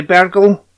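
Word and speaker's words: Bergl: It stars Emily Bergel. Is that how you say her name? – Yeah.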